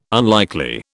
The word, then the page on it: unlikely: British English